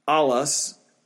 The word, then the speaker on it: allos: It's pronounced allos, A-L-L-O-S, allos, and it means another of the same kind.